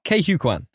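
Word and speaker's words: Ke Huy Quan: Kay Hue Kwan.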